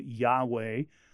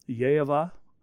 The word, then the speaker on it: Yahweh: It is, you know, Americans usually pronounce it Yahweh, though no one really knows how it's pronounced. Uh, Jews were forbidden to ever say it out loud. Um, Yehovah, um, it's more like breath sounds than anything else. But often in the United States, we just try and make it simple by saying Yahweh. I know it's not pronounced like that in reality, but that's how Americans usually do it.